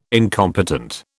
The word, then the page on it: incompetent: British English